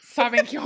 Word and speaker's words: Sauvignon: Yeah, Sauvignon. That's how you say it.